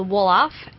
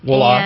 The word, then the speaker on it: Wolof: but correct my pronunciation and tell us about the language. – So the language is called Wolof. – Wolof.